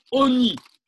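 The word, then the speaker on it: Oni (עֹנִי): Pour faire l'allusion au pauvre, c'est ce qu'on a dit tout de suite, mais ça se prononce Oni.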